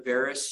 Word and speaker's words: varus: So if you're doing the varus stress, you will be checking the medial meniscus and, am I saying that right?